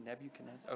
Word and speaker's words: Nebuchadnezzar: Nebuchadnezzar. I think that's right. Right, Ashley? Nebuchadnezzar? Okay, thank you.